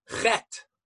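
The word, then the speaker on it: chet (חטא): Spelled with three letters. Ches, tes, aleph. An aleph at the end is not pronounced. You put two dots under the chet and it's pronounced chet, period.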